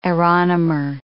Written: aeronomer

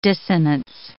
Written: dissonance

